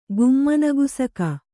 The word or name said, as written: ಗುಮ್ಮನಗುಸುಕಿ